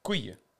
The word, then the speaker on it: couille: Les couilles. – It's because it's one of the hardest words for English speakers to pronounce. – How's my pronunciation? – It's like ratatouille, couille. – Couille.